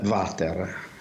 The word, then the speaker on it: water: Ecco, per esempio, noi diciamo il water, no? Cioè pronunciamo quella W che in realtà è una U, come quella di uomo, tanto per capirci, una U semiconsonantica, però lo pronunciamo come se fosse V, e quindi diciamo il water, il Winchester, anche se è Winchester naturalmente, il Windsor, va bene?